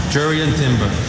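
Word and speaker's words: Jurriën Timber: Jurrien Timber.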